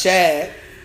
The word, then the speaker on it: Shad: It's Shad. Get put some Shad. Why do you give him a short A? That's not his name.